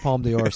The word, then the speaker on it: Palme d'Or: Palm d'or? Is that how you say it? – Yes, the Palme d'Or strike.